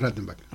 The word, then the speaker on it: Rattenbach: el informe Rothbach. – Rottenbach, siempre lo pronuncio mal, de hecho. Y ahí, bueno... – En realidad es Rattenbach.